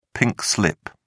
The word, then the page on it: pink slip: British English